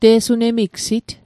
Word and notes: te'sunemigsit: Mi'gmaq

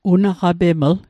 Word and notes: unaqapeml: Mi'gmaq

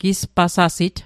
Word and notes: gispasasit: Mi'gmaq

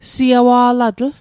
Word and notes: siawa'latl: Mi'gmaq

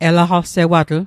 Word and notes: elaqalsewatl: Mi'gmaq

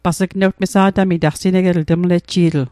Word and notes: mesa'tamit: Mi'gmaq

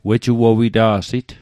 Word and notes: wejuowita'sit: Mi'gmaq

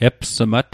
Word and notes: epsmat: Mi'gmaq

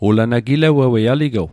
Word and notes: gilewewei: Mi'gmaq

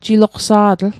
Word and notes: jiloqsa'tl: Mi'gmaq

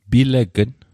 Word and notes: pilegn: Mi'gmaq

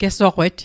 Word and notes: gesoqet: Mi'gmaq

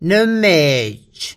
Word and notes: nme'j: Mi'gmaq

